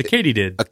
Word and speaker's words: katydid: Oh, Lord, I'm going to mispronounce this. – It's a Katydid.